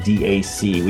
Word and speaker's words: DAC: The ADI-2 DAC is the product I'm referring to, and it's ADI-2 DAC. We just say DAC when we pronounce it.